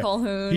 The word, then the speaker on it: Colquhoun: Colquhoun. – Colquhoun. – Colquhoun, yeah. – I think it's a silent Q. – Yeah, there's a silent Q. – Yeah, Colquhoun.